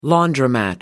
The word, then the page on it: laundromat: British English